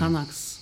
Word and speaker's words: Xanax: Ama Z ile okunuyor. Xanax.